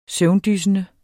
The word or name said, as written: søvndyssende